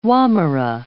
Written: wommera

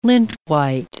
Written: lintwhite

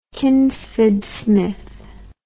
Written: Kingsford Smith

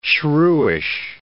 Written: shrewish

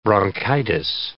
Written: bronchitis

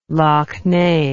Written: lough neagh

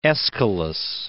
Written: Aeschylus

